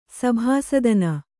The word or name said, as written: ಸಭಾಸದನ